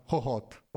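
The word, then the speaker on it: Hohhot: Hohot, ok.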